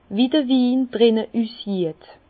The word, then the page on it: wie seht d'r Win drii: Bas-Rhin; Herrlisheim